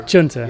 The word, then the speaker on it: chunter: Chunter. – Yeah. Chunter's much better than me. I've got the unfortunately the flat American pronunciation.